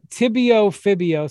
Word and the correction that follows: tibiofibular: So you have a tibia in your leg and a fibula, the long tibiofibular.